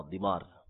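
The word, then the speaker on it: ضمار: ضاد میم الف را، دیمار، دیمار اس کا صحیح تلفظ ہے، بعض نے اس کو دمار بھی پڑھا ہے،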